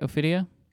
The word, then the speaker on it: Ophidia: Gucci's Ophidia case for... Is that right, Federico? Am I saying that right? Ophidia? – Let's see. – You know all the classic names. – That seems about right, yeah.